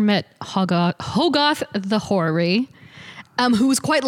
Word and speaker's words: Hoggoth: Agamotto, and Hoggoth. Gosh, Hoggoth. It's a literal pronunciation. Nice. Hoggoth. So Ashtar, or Ashtar, O-S-H-T-U-R, Ashtar. – O-S-H. – T-U-R.